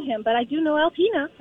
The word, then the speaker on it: Alpena: So the name is Alpena. Yes! I grew up near there. It's Alpena.